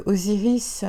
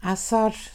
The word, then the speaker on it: Osiris: Par la même occasion, vous savez que Osiris, euh, Osir, Asar, ça dépend comment on veut le prononcer.